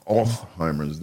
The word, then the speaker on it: Alzheimer's: It ain't Alzheimer's, it's Alzheimer's. You gotta use the German part, Alzheimer's, that's what it is.